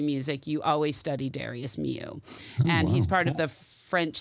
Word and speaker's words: Milhaud: He studied composition with Darius Milhaud, and it's spelled Milhaud for you, but it's pronounced Milhaud, and he is considered one of the top French composers of the 20th century, of the classic style.